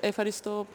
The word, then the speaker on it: Ευχαριστώ: Ευχαριστώ πάρα πολύ.